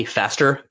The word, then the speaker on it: Pfaster: That was the one with Donnie Faster, P in the PFASTER. By the way, another don't like old people. Also, don't ever trust anyone with a silent letter in their name. Donnie Pfaster.